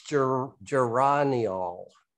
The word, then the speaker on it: geraniol: Well, it says Webster says it's geraniol. Geraniol.